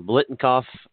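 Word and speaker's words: Biletnikoff: The Blitnoff – I said that, I always say that wrong. Blitnikoff Award for wide receivers, best wide receivers list.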